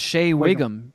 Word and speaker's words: Shea Whigham: How do you say it? – I think it's just Shea Whigham.